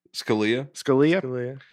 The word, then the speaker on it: Scalia: But Justice Scalia had to write the opinion on it. Did I say that wrong? – Scalia.